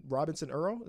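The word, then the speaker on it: Robinson-Earl: Robinson Earl, is I'm pronouncing that right? – Yep.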